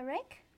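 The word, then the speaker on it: riq: I think so, the reek. – Yeah. – Reek or reek? – Reek.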